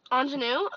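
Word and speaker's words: Aunjanue: How do you pronounce that? – Ingenue? – Oh, that's right. That's definitely right. Ingenue Ellis, King Richard.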